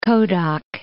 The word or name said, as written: Kodok